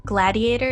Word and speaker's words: GLADIATOR: Gladiator, is that how you pronounce it? – Yes.